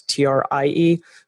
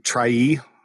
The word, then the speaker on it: trie: I've never learned how to pronounce that. It's TRIE, but you can get a lot of data sharing out of those data structures as well. So that's Pronounced TRIE? – TRIE, maybe.